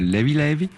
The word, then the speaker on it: Levé Levé: Alors volume 2 donc de Levé Levé, qui se prononce là-bas Levé Levé, ça veut dire léger léger, ou tranquillou un peu, c'est, en gros il n'y a pas de stress.